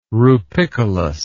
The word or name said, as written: rupicolous